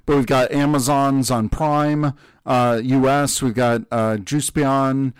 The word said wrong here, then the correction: Just Beyond: I'm probably saying that wrong. – Just think Beyond, so Justice and Champion. – Oh, Just Beyond.